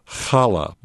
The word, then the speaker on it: challah: And Lee Malhado will be teaching people how to bake challah bread. I think that's the correct pronunciation, C-H-A-L-L-A-H. That's Sunday, July 23rd at 1:30.